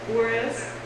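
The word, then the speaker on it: Juárez: Is it Juarez? – Yeah. Mm-hmm.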